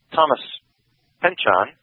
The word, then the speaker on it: Pynchon: So Thomas Pynchon, did I say that right? – Yes, yes. – P-Y-N-C-H-O-N? – Yes.